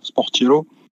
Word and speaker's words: Sportiello: Ouais, ben Sky a fait fuiter une compo là en début de soirée avec du coup leur gardien suppléant là, Sportiello. Mathieu, tu me pardonneras pour la prononciation. – Non, c'est ça.